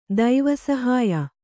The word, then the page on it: ದೈವಸಹಾಯ: kannada